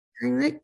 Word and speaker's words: Heimlich: Is that how you say it? Heimlich maneuver? – Heimlich.